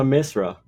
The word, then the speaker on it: Misra: Kira Misia. Misra? I can't get her name right. It's a TD security strategist, Pira Misra. The strong data will force the central bank to remain aggressive and pull economic activity lower.